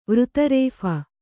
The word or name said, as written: ವೃತರೇಫ